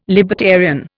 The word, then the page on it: libertarian: Indian